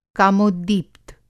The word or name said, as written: कामोद्दीप्त